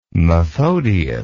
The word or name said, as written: methodius